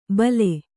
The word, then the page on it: ಭಲೆ: bhale